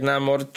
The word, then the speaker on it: Namor: Nie wprowadzasz takiej postaci jak Namor czy Namor, ale jak się okazuje z filmu, obie wymowy są poprawne, ciekawe.